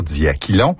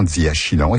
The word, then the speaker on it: diachylon: En principe, il faudrait dire diaquilon, mais la prononciation diachylon est acceptable.